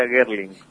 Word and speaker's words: Guerlin: En realidad se escribe así, pero se pronuncia Guerlin.